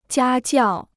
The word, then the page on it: 家教: jiā jiào